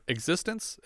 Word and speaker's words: eXistenZ: He's also in Cronenberg's films The Brood, Naked Lunch, and Existenz, or Existence, as I've just learned some people pronounce it.